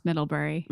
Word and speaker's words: Middlebury: It's just pronounced Middlebury. – Middlebury.